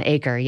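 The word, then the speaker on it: Achor: There's this author called Sean Anker. – Oh, Sean Aker, yeah, from Harvard. – Butcher his name. – Yeah, Aker.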